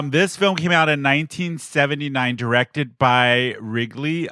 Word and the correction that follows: Ridley: Uh, did I say that right? Wrigley Scott. – Ridley, Ridley Scott.